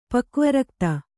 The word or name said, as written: ಪಕ್ವರಕ್ತ